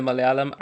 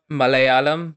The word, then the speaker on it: Malayalam: So the two L's in the Malayalam are pronounced differently. – Oh yeah? – So it's not Malayalam, but it's Malayalam.